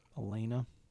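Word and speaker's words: Alana: Jamie Lee Curtis as Elena, which is Elena, right? It's spelled weird, too. It's A-L-A-N-A. – Yeah. – Elena.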